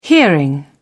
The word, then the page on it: hearing: British English